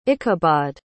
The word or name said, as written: ichabod